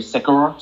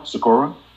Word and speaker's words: Sekura: S-E-K-U-R-A, Sakura? – Sakura.